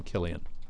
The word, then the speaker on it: Cillian: I believe he pronounces his name Killian.